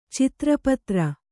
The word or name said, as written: ಚಿತ್ರಪತ್ರ